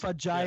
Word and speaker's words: fajitas: Fajitas, you know? They're pronounced fajitas.